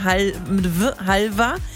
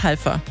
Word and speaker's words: Halver: Aber es gibt mal so, mal so. Ich habe extra hier recherchiert. Duden angeblich mit Halver. Wir haben so eine Aussprache-Datenbank hier, ganz offiziell. Die sagen auch Halver. Aha. Dann habe ich aber gehört, viele bei euch sagen Halfa. Du sagst Halfa.